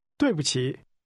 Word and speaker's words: 对不起: 对不起.